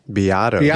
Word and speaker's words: Beato: Beato.